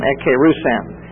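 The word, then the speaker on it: ekērussen: And it's spelled E-K-E-R-U-S-S-E-N, ekkerusen.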